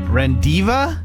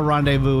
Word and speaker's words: rendezvous: R-E-N-D-E-Z-V-O-U-S. R-E-N-D-E-Z-V-O-U-S. – Rendiva?